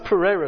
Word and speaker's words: Pereira: I mean, I think it should be pronounced Pereira, shouldn't it? – Is it Spanish? – Portuguese. Portuguese. Pereira.